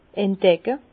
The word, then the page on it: entdecke: Bas-Rhin; Herrlisheim